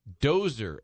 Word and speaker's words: Dozer: And I'm asking people, how do you pronounce it? And they said, Dozer, as in bulldozer.